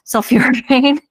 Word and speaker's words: Sulforaphane: Sulforaphane.